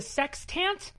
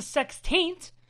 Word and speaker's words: Sextant: So the issue name is The Sextant. It's not pronounced The Sextaint.